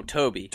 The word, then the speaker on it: Tobe Hooper: So how do you say it, Toby or Tobe Hooper? – Oh, Toby.